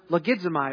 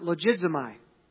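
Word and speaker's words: logizomai (λογίζομαι): The Greek word for meditating here in this passage is the word logizomai, or you might even pronounce it logizomai.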